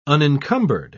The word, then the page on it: unencumbered: British English